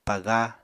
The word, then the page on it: paga: Apatani